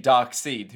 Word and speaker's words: Darkseid: I think that guy's name is pronounced Darkseid, even though it's very much not spelt like that.